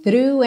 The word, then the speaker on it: through: O GH do final é mudo e through é fechadinho. Lembra só de começar th, com a linguinha lá na ponta dos dentes da frente. Through, ok?